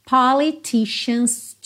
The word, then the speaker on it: politicians: We got doctors, lawyers, politicians too. Atenção aí também a pronúncia de politicians, ok? Com esse sh, sh aí.